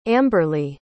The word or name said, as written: amberlee